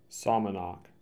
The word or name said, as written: Somonauk